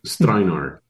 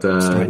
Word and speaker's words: Strajnar: Very close. Yes. Strajnar. But Strajnar, look, I've heard it all. Stranger, Steinlager, yeah.